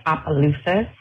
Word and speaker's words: Opelousas: Yes, you did say it wrong. It's Opelousas, our Cajun names, I guess.